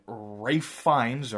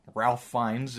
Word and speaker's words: Ralph Fiennes: And uh, yeah, so and they've also got uh, Rosario Dawson as Barbara Gordon and Rafe Fiennes or Ralph Fiennes, depending how you want to pronounce it.